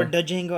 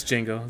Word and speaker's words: Django: Or Da Django, I don't know how you say that. – It's Django, the D is silent. He says so in the movie.